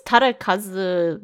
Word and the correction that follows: Takarazuka: Revue. – Is the name of it? – Yeah, see, I just can't pronounce it. – I'm sorry. It's Takarazuka Revue. Takarazuka. – Takarazuka Revue.